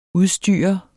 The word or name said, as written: udstyre